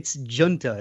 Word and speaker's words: Gunter: Oh, close. It's Gunter. It's Gunter. Italian.